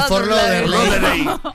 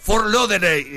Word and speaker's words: Fort Lauderdale: El panadero de Fort Lauderdale. – Lo escuché tantas veces que ahora creo que lo digo bien. – A ver. – Fort Lauderdale. – ¡Ay, no, casi! No, ¡casi! – Fort Lauderdale.